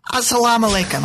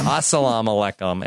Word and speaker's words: Assalamu alaikum: Alam Alekum. Did I say that right? – Assalamu alaikum. – Assalamu alaikum.